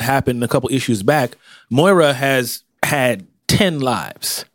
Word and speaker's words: Moira: These lives of Moira? – Yeah. – Am I pronouncing it right? – I believe so.